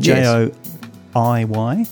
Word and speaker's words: Joiy: So Joiy, is that how you pronounce it? – J O I Y. – That's correct. Yeah, yes.